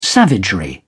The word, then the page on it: savagery: British English